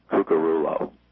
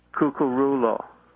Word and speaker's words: Cucurullo: If you had a double L, that would be a Y, pronounced as a Y. – And then there's some Italians that say the double C is cooch. But I actually, it's just very simple. It's Cucurullo.